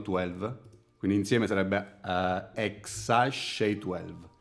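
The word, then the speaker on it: X Æ: si pronuncia ex Ash.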